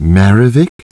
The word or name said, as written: Maravic